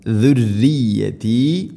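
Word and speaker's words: ذريتي: വമിൻ ദുർരിയതി ഇനി ദുർരിയതി റാഇന് ഷദ്ദുണ്ട്, എന്നാലും ദുർരിയതി പറയരുത്, തർക്കീഖ് ചെയ്യണം, നേർപ്പിക്കണം. ദുർരിയതി ദാലിൻ്റെ ഉച്ചാരണം ശ്രദ്ധിക്കുക, സുരിയത്തല്ല, ദുരിയതി.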